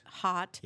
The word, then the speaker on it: Haute: It's Hottie Diggity. Am I saying that right? – Hot Diggity Dog. Oh, I see. H-A-U-T-E.